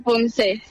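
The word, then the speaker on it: poomsae: La de combate, que sí que es olímpica, la otra, ya lo pronuncias tú, por aquí me sale Pumse. – Se llama Pumse, sí. – Pumse, vale, le tengo que quitar la A, entonces.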